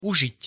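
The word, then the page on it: uzit: Slovak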